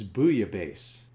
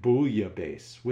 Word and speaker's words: bouillabaisse: It's bouillabaisse. I always pronounced it bouillabaisse with the L. Despite my many years of French, both semesters, I forgot about that whole dropping the last letter sound thing.